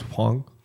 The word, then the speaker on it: Huang: Yeah, they said Huang, but he's like, my name's Huang.